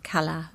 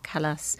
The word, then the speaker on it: Callas: How do you pronounce that? – I think whatever way you want to. It's, Kala or Callas.